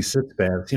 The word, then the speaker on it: sitz bath: Well, and whenever I see sitz baths, it's spelled S-I-T-Z. Henry and I are both native German speakers, and I think, no, it's sitz bath, which is the German word for sitting.